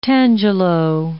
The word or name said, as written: tangelo